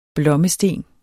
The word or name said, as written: blommesten